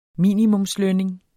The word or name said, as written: minimumslønning